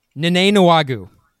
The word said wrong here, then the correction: Kene Nwangwu: Kene Nwangwu. I didn't even pronounce it right.